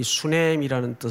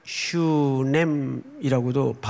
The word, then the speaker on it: 수넴: 그래서 굉장히 좀 위쪽에 자리 잡고 있는데 이 수넴이라는 뜻은 원래 슈넴이라고도 발음을 해요.